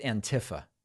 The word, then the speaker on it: Antifa: They pronounce it Antifa tragic. Now. It's pronounced Antifa.